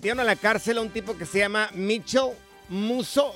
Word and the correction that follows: Mitchell Musso: Mitchell Musso.